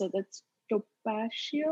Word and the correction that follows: tapioca: or topacio. I don't know how to pronounce it. I but I know I can't yeah. even. Uh, what is it? – Tapioca. Tapioca. Yeah.